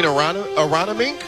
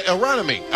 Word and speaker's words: Aronimink: You mean Aronimink? Aronimink. I believe I learned how to say it correctly.